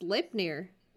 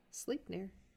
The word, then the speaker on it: Sleipnir: Pronounced Sleipnir. Like no. Slay with a P dash N-I-R. Sleipnir.